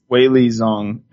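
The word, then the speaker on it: Weili Zhang: Wei Li Zhang, and buddy, you better get woke to Wei Li Zhang, all right? – I need to get woke to her, yeah.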